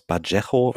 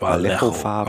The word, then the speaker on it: Vallejo: wie spricht man es auf Spanisch richtig aus? Bajejo, Vallejo-Farben.